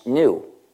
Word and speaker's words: gnu: le G est muet et le mot se prononce new, c'est-à-dire nouveau. Donc, chaque fois que tu veux écrire le mot new, en lieu de l'écrire N-E-W, tu peux l'écrire G-N-U, c'est un jeu de mots.